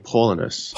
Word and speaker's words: Paulinus: But I thought that it was interesting that the way our character, Paulus, is that how we're pronouncing his name? – I was saying Paulinus. – Paulinus, yeah, that sounds right. – I think there's an I in there.